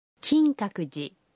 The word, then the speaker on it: Kinkakuji: La prononciation est Kinkakuji.